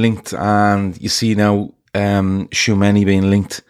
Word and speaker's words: Tchouaméni: So the first one I want to talk about is Tchouaméni at, and I hope I'm saying that right, I asked Keith earlier how I pronounce his name. He said Tchouaméni, the midfielder from Monaco.